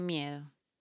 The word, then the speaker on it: miedo: Miedo. – How do you spell that? – M-I-E-D-O.